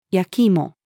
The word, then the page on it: 焼き芋: female